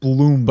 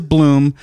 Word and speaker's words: Blomberg: Bloomberg. – But it's Bloom. You pronounce it Bloom, but if you want to not confuse yourself, it's Blom.